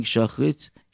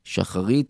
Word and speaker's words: Shacharit: shachrit shacharit shachris whatever you call it in uh, in in your pronunciation we said, והריני מקבל עליי מצוות עשה ואהבת לרעך כמוך.